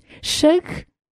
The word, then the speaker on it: Shug: Shug é a primeira sílaba de sugar, açúcar.